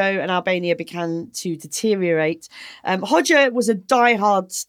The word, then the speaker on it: Hoxha: Hoxha. – How do you say Hoxha? – Hoxha. – It rhymes with Todger.